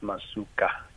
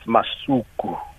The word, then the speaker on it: Masuka: And she did emphasize that her surname is not pronounced Masuka. It's Masuku.